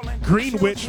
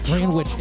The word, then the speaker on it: Greenwich: Greenwich Village. – Greenwich. It's not pronounced that. It's Greenwich.